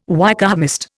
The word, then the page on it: Wykehamist: Indian